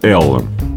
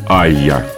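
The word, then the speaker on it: Allá: Если читать по-английски, получается Ella. Но сайт лейбла Crammed Disc утверждает, что это испанское название нужно произносить как Aya. Итак, Aya – это трое живущих в Чикаго музыкантов мексиканского происхождения.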